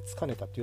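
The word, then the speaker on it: 束ねた: さらに「死のをつかねた雨」つかネタっていうのはあの束ネタと書いてつかネタって読むんですけどあの篠を束,、ね、束にしたような太い雨がて激しく降ってるんだと。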